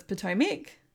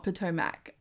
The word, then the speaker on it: Potomac: First of all, I've been told by Hannah, who was on episode 2, that it's Potomac, not Potomac, as you and I have emphasized every episode.